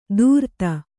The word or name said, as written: ಧೂರ್ತ